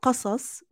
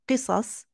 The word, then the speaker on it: Qasas: The word Qasas, which is also pronounced as Qisas, both are correct pronunciations, Qasas and Qisas.